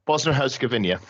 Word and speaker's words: Bosnia-Herzegovina: I can't pronounce that, I'm afraid. – Bosnia-Herzegovina. – Thank you very much.